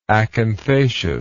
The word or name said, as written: acanthaceous